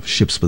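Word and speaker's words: Shjips: That was Wooden Ships Remixed, a track off of the West LP released last year from Wooden Ships, Ships with a Silent J, a four-piece out of San Francisco.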